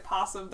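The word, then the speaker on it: opossum: You have to come to terms with that and the fact that possum, the O is silent.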